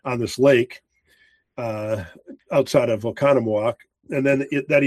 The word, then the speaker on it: Oconomowoc: And they instead found a place in Oconomowoc, Wisconsin.